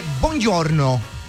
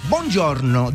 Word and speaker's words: buongiorno: Mire, en italiano se dice buongiorno. Buongiorno, dígalo.